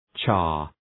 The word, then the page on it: char: ʃɑ:r